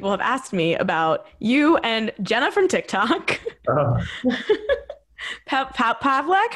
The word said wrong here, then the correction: Palek: Jenna Palik.